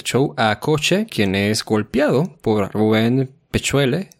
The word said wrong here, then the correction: Peuchele: o Pecuele, ahorita no me acuerdo cómo se pronunciaba. Y ahorita tú me Peuchele. – corregirás. Peuchele, muchas gracias.